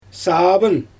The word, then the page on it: saabyn: Xyzyl